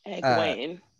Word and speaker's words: Egwene: But think of it as like two words, like eh and then Gwaine, like Gwaine.